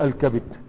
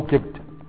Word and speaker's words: كبد: فالمعرفة دي اللي فيها الألف واللام تنطق الكبد بالفتح والكسر وأما اللي هي نكرة بدون الألف واللام فلك فيها أنك تقول إيه كبد أو كبد علشان ما ينكسرش البيت.